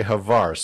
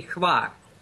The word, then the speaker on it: Hvar: Now you pronounce it Hvar, and I always say Hvar. So is that the correct pronunciation then? – Well, for you, that letter hotel would be a little bit difficult to pronounce, so the right pronunciation would be Hvar.